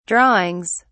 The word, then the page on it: drawings: British English